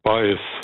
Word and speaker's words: Baez: Sagt mir der Joan Baez oder Baez? – Baez. – Baez, gell? Ja. Latinisch ausgesprochen.